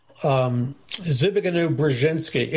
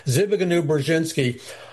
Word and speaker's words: Zbigniew Brzezinski: And then in 1976, you had a book written by, and my books cover all of this in further expanded detail, but Zbigniew Brzezinski, it took me forever to learn how to pronounce his name, but that's how you pronounce it. Zbigniew Brzezinski, who was co-founder of the Trilateral Commission with Rockefeller, which was a globalist activist think tank owned by billionaires.